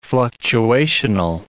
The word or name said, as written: fluctuational